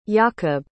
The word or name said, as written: jakob